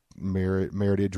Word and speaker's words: Meritage: Meritage rhymes with heritage. It's merit and heritage.